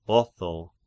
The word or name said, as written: Otho